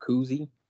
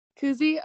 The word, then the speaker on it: Cousy: How do I say that? – Is it Cousy? – Cousy, okay.